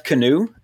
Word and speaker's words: Kanew: It was directed by Jeff Kanew. That is his last name, but it's spelled K-A-N-E-W. It's like if a band wanted to call themselves Kanew, that's how they spell it. So, yeah, Jeff Kanew, K-A-N-E-W.